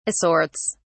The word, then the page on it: assorts: British English